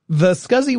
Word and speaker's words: SCSI: It was the first Macintosh to feature a small computer system interface, also known as a SCSI drive, S-C-S-I, but people call it SCSI. Not that it was SCSI, it's just how we pronounce it.